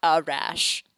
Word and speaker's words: Arash: That's spelled A-R-A-S-H. It's not Arash. It's Arash. I know I used to call him Arash, and I was corrected by my mother.